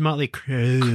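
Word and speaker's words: Mötley Crüe: It's like Motley Crue. Like, Oh, look, two dots. – Crew. – Well, it must be important because it's got dots over it. – It's pronounced Motley Crew.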